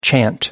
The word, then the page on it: chant: American English